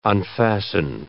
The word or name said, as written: unfasten